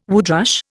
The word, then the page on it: woodrush: Indian